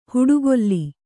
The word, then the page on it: ಹುಡುಗೊಲ್ಲಿ: huḍugolli